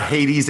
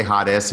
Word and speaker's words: Hades: Did you say Hades? You're doing the original pronunciation or something? Is that what you're doing here? – Yeah, Hades. Hades, the Greek, Gehenna, the Hebrew, which are somewhat different, but they have some similarities, correct?